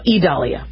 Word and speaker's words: Idalia: They are pronounced, oh, it's Idalia. No, Idalia.